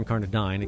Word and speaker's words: Incarnadine: Two collections of poetry, Granted and Incarnadine, or Incarnadine, it can be pronounced either way, which won Mary the National Book Award for Poetry just last year.